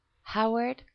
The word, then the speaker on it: Howard: My name is Howard Clark.